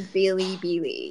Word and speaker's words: Bilibili: It's pronounced as Bilibili. Yeah, Bilibili. – Billy yeah, there's more of an E in there. More of an E. Too much of the I.